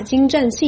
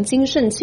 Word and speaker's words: 精湛: 先是在一带一路峰会开幕式上，习近平把精湛细腻错读成精慎细腻，随后在官媒发送的新闻稿中，他们把此处改成了精紧细腻，以掩饰习近平念错稿的事实。